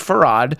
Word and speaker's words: Fard: Fard Muhammad, but it is pronounced Farad.